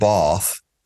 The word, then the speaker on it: Bath: Yeah, but the pronunciation's still Bath. It's not Bath.